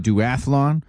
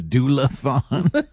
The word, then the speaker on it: Duathlon: I mispronounced it as the doula-thon.